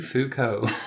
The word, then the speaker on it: Foucault: Michel Foucault. Is that how you say his name? – Yeah. – Foucault.